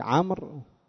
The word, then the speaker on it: عمرو: طيب عمرو وعمر، عمرو وعمر الصورة واحدة، عين ميم راء، عين ميم راء، وإن كان تلك لها واو زائدة عند الكتابة، لكن النطق مختلف أم واحد؟ النطق، النطق مختلف، ذاك عمرو وذاك عمر.